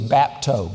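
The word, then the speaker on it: bapto (βάπτω): The first one in your notes is bapto, B-A-P-T-O. All right, that's the way it's pronounced from the Greek.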